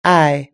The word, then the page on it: eye: British English